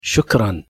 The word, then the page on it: obrigado: arabic